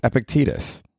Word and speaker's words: Epictetus: It's Epictetus.